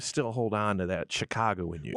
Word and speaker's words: Chicago: And I want you to pronounce Chicago the right way, not the way. – It's Chicago. – Yeah. – Chicago. – Not the way I say it.